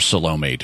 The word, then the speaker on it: Salome: So that's who Salome is. Or Salome, to pronounce it the more American way.